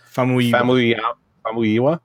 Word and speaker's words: Famuyiwa: Famuyiwa.